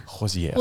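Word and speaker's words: Hozier: Hosier.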